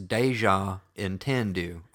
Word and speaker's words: Déjà Entendu: Dude, we are from South Carolina. It's Déjà Entendu.